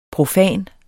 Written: PROFANT

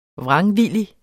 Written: vrangvillig

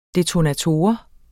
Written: detonator

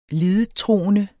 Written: lidettroende

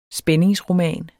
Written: spændingsroman

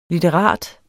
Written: litterat

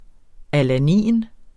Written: alanin

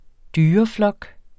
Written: dyreflok

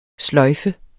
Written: sløjfe

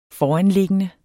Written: foranliggende

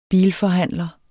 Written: bilforhandler

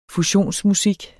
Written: fusionsmusik

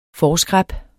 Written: forskræp

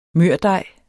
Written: mørdej

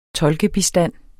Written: tolkebistand

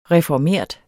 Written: reformert

